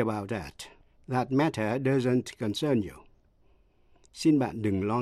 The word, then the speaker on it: concern: Hai từ nữa là concern, C-O-N-C-E-R-N, là quan tâm hay là liên hệ đến, và consent, C-O-N-S-E-N-T, nghĩa là thỏa thuận. Dù là danh từ hay động từ đều nhấn ở vần nhì và phát âm y hệt nhau.